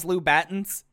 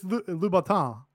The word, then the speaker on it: Louboutin: Yeah, Southern here, and we haven't heard someone say, "Did you see them in those Louboutins?" No, I say Gangrel, and I. – It's Louboutin. – Louboutin. Louboutin. Yeah, which is how you pronounce it.